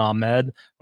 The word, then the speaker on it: Ahmed: Miles Gaskin, he's likely to return this week with little to no competition for touches, especially with Salvin Ahmed. I am going to say Ahmed now because I finally agree with our boy George Reed from Break from the Grind. That is how he pronounces his name.